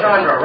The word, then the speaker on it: Chandra: Chandra rhymes with tundra.